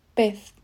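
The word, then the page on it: pez: Spanish (Spain)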